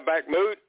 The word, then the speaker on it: Bakhmut: The Russians have encircled Bakhmut, I guess. Is that how you pronounce that city? – Yeah, Bakhmut.